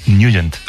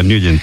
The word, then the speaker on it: Nugent: Hombre, siempre lo hemos llamado en el barrio Tetnagent o Tetnagent. – Claro, ¿verdad? Yo era de Tetnagent. – Pero la pronunciación correcta es Nugent. – Te fuiste a Washington y descubriste que era Nugent.